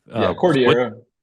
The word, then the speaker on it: Cordillera: So, forgive me if I'm not pronouncing it correctly, Cordillera? – Cordillera.